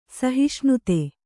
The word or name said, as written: ಸಹಿಷ್ಣುತೆ